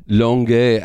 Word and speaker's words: Longueuil: Longueuil.